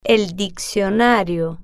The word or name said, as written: el diccionario